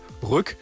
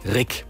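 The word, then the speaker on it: Ryck: Und hier in Greifswald haben wir einen Fluss, der wird auch gerne mal Rück oder Riek ausgesprochen, der heißt aber Rick.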